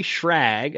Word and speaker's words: Schrag: Alright guys, this week I'm on the phone, or the line I should say, with Loren and Alli Schrag of Good Bowl Outdoors.